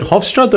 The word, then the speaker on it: Hofstra: You have a bachelor's degree in psych, from, is it Hofstra? Am I pronouncing that correctly? – Yep, you got it.